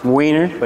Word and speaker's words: Wehner: Pretty close. It was Wehner.